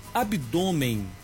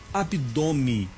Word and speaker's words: abdômen: Há palavras que aceitam escrever de dois modos e de pronunciarem também. Eu vou comentar com vocês algumas palavras aqui. Ouça, abdômen e abdome. Eu posso escrever abdômen com acento no O e o N no final, abdômen.